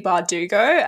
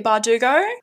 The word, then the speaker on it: Bardugo: Well, this is like when people would pronounce Leigh Bardugo as Leigh Bardugo.